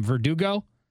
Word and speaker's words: Verdugo: Verdugo? Anyway. – I'm not sure. – V-E-R-D-U-G-O? – You did a good job.